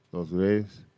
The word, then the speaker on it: Los Reyes: Did I say that right? Los Reyes. You got to put it. If you're from Spain, you have to do this. – Yeah. – That is because the king had a lisp, right? – Apparently something like that.